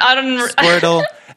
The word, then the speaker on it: Squirtle: Squirtle. And you pointed it out here. Squirtle rhymes with turtle.